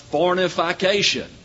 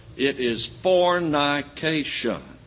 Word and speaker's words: fornication: I keep hearing it pronounced fornification. There is no such word, brethren. It is fornication.